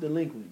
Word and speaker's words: Dlequint: Y'all need y'all to say it's pronounced delinquent, bitch. – That's about 3-4-5.